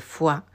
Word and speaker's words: Foix: Hoy vamos a hablar de una reina medieval, bastante conocida dentro de todas las medievales, que es Germana de Foix o Germana de Foix, si atendemos a la pronunciación francesa de su apellido, porque precisamente su apellido venía de la ciudad donde había nacido, en Francia.